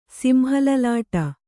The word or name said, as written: ಸಿಂಹಲಲಾಟ